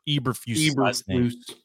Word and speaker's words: Eberflus: Eberflus.